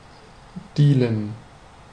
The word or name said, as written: dealen